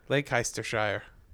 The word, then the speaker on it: Leicestershire: I know how to say it. – Do you really? – Yeah. – What is it? – Leicestershire. – Yep. What he said.